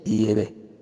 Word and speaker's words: Yahvé: Y es Yahvé, que es la pronunciación más correcta en hebreo arcaico.